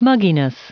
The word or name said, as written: mugginess